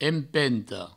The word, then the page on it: empenta: empento